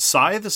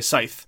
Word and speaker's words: scythe: One of them is the way you routinely, and I sometimes say the word scythe, sometimes we say scythe, which is a legitimate pronunciation of the word.